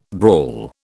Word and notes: brawl: British English

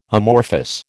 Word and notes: amorphous: American English

